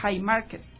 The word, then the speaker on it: Haymarket: en la revuelta de Haymarket.